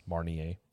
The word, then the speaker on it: Grand Marnier: Grand Marnier. – I'm just saying, you took French. I thought you could. – It's Grand fine. Marnier. – Marnier.